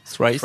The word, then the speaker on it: Thrice: Alors le premier c'est Thrice, c'est ça, ça se prononce comme ça Thrice.